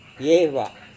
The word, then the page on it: vieva: Serewen